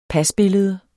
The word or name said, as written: pasbillede